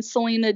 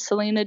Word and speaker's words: Salena: It's just Dean, S-T-E-R 95. And then on Facebook, me and Selena Dean. And then the same is on Twitter, me and Selena Dean. And it's Selena, S-A-L-E-N-A.